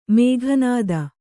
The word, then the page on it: ಮೇಘನಾದ: Kannada